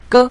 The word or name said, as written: ge5